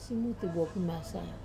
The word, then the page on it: simuti pimasa: wamesa